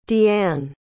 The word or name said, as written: deann